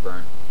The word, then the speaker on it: Byrne: David Byrne.